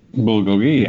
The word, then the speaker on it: bulgogi: You said you've got, how do you pronounce it? Bulgogi? – Bulgogi, yeah.